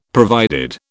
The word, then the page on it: provided: British English